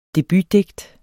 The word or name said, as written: debutdigt